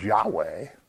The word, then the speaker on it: Yahweh: So depending which vowels you put in it, but the accepted pronunciation is Yahweh.